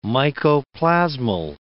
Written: mycoplasmal

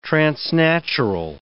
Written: transnatural

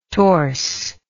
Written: torse

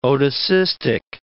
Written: otocystic

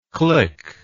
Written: klick

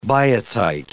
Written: biotite

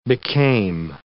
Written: became

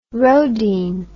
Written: roedean

